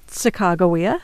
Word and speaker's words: Sacagawea: They also had this woman, Sacagawea, whose name we now know is pronounced Sacagawea.